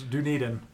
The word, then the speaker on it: Dunedin: Yes, Dunedin. – Dunedin. – And you can pronounce it the English way or the proper English way, and it's slightly different, but we'll do as best as we can as Americans to describe it properly.